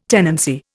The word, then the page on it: tenancy: Indian